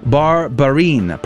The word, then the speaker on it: Barbarin: Did I say his name correctly? I think I just obliterated his poor name. Cardinal Philippe Barbarin, probably is how you say that. Barbarin, Cardinal Barbarin, the Archbishop of Lyon, southern France, and sent their approval to the Vatican for the Pope's consideration.